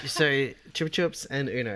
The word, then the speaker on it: Chupa Chups: It's pronounced Chupa Chups. – Yeah! – In the US. – Yeah, well, and Chupa Chups in the UK.